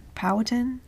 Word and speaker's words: Powhatan: Powhatan? – Yeah.